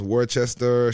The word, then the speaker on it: Worcester: You wouldn't even guess the town, like Worcester, which is pronounced Worcester, Sharon.